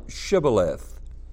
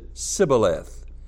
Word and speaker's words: Shibboleth: If he said, Nay, then said they unto him, Say now, Shibboleth. And he said, Sibboleth, for he could not frame to pronounce it right.